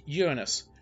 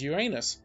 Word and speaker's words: Uranus: Of course, astronomers pronounce it as Uranus, while everyone else pronounces it as Uranus.